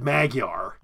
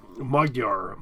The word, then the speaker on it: Magyar: Oh, they apparently said Magyar. – Uh-huh. – And it should be pronounced more like Magyar, something like that.